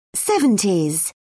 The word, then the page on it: seventies: British English